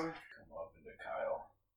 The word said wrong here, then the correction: Kiel: I told you it's pronounced Kiel, but you insisted.